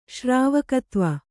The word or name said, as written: ಶ್ರಾವಕತ್ವ